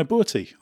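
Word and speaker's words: Burti: That driver is Luciano Berti. I hope that's a reasonably close pronunciation. I've been trying to practice it. We always say Berti in England, not quite right.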